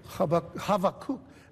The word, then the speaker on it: Habakkuk: I'm saying it that way because that's actually the Hebrew pronunciation, Habakkuk. Actually, it's not a B, you know, in the English it's B, but it's actually more of a V sound, Habakkuk. Like if you're telling somebody have a cookie, but don't add the last, you know, syllable.